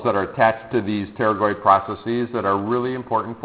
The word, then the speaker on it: pterygoid: Okay, pterygoid muscles that attach to the pterygoid process. All right, so I think even though it's spelled with a P, I want everybody to say what this process is.